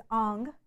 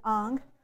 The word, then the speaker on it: Ingres: His name is Ang. You would pronounce it Ang. It's spelled Ingres, I-N-G-R-E-S, but it's pronounced Ang.